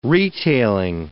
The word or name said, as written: retailing